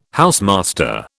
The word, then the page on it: housemaster: British English